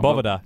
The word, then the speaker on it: Bovada: Bovada. – Bovada.lv. – Uh-huh. – I always have to remind myself it's pronounced like Nevada.